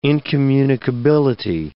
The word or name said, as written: incommunicability